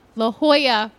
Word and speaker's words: La Jolla: After 4 years of trying out the material with readings and workshops, Crybaby premiered at the La Jolla. I said it wrong? – Yeah, no, that was right.